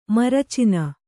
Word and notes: ಮರಚಣ: kannada

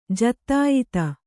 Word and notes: ಜತ್ತಾಯತ: jattāyata

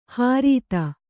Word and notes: ಹ್ರೀತ: hrīta

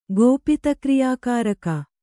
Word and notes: ಗೋಪಿತಕ್ರಿಯಾಕಾರಕ: gōpita kriyā kāraka

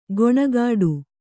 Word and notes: ಗೊಣಗಾಡು: goṇagāḍu

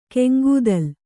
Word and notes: ಗಂಗೆದೋಲು: Gaŋgedōlu

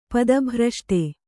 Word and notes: ಪದಭ್ರಷ್ಟ: pada bhraṣṭa

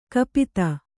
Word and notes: ಕಪಿತ: kapita

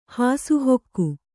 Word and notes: ಹಾಸುಹೊಕ್ಕು: hāsu hokku

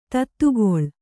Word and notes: ಧಾತುಗೊಳ್: Kannada

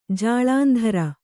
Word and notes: ಜಾಳಂಧರ: jāḷandhara

